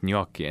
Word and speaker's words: gnocchi: G-N-O-C-C-H-I, by the way. Gnocchi is how you pronounce that word.